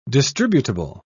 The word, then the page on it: distributable: British English